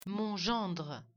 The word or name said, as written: gérer